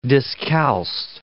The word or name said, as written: discalced